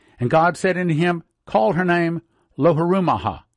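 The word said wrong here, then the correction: Lo-ruhamah: Now when she had weaned Loruhamah, pronouncing it correct, she conceived and bare a son.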